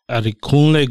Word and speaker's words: Adekunle: Adekunle Gold.